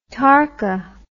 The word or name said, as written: tarka